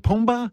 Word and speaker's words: Pumbaa: Pumbaa? – Pumbaa's right.